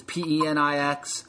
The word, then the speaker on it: Penix: Be careful how you pronounce that, by the way. That's the running joke in all the Ohio State media this week. It's Penix. I mean, you do the spelling. What does that spell like?